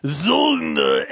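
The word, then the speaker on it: Söldner: S-O-L-D-N-E-R, correct? – Yeah, and but when you're playing it, they pronounce it with a Z. Zulgna X!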